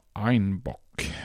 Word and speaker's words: Einbeck: Och på den bayerska dialekten uttalades tydligen Einbeck som Einbock.